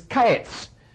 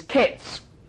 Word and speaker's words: ketz: The Hebrew word for summer fruit is kaetz, and the Hebrew word for end is ketz. Both of them are pronounced then with a broad Australian accent. But even with that accent, kaetz and ketz sound very much alike.